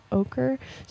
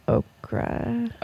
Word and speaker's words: ochre: Yellow ochre. So ochre is how you say that? I always say okra. – Ochre. – But that's wrong. – Okra is a vegetable.